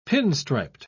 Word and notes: pinstriped: British English

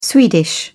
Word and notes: swedish: British English

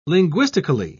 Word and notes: linguistically: British English

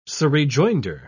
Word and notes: surrejoinder: British English

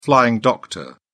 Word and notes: flying doctor: British English

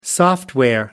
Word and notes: software: American English

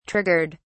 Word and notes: triggered: British English

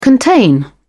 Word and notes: contain: British English

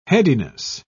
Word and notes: headiness: British English

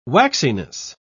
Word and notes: waxiness: British English

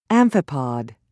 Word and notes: amphipod: American English

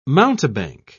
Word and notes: mountebank: British English

